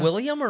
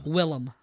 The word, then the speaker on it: Willem: William or Willem? – Willem. – It's Willem? – Yeah, W-I-L-L-E-M.